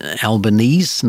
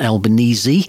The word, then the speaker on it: Albanese: It is Albanese, not Albanese. So whether or not people will change that pronunciation, Albo, I think, is much easier.